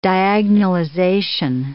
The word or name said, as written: diagonalization